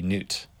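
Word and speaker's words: Gnute: That's G-N-U-T-E, Newt.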